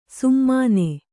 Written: ಸುಮ್ಮನೆ

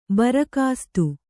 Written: ಭರಕಾಸ್ತು

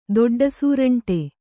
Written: ದೊಡ್ಡಸೂರಂಟೆ

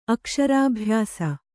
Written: ಅಕ್ಷರಾಭ್ಯಾಸ

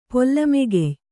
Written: ಪೊಲ್ಲಮೆಗೆಯ್

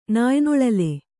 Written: ನಾಯ್ನೊಳಲೆ

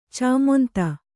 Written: ಚಾಮೊಂತ

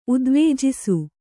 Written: ಉದ್ವೇಜಿಸು

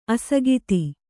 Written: ಅಸಕ್ತ

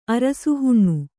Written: ಅರಸುಹುಣ್ಣು

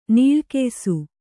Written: ನಿಲಗಾಯಿಸು

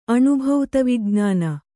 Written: ಅಣುಭೌತವಿಜ್ಞಾನ

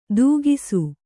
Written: ತೂಗಿಸು

